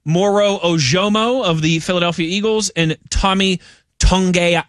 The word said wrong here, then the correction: Togiai: Is it Tongai? Is that how he pronounces his last name from the Texans? – Togiai. Yeah.